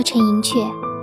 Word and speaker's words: 陈寅恪: 而且现在很多电视节目中也是这样叫法，所以我在这里也读作陈寅恪。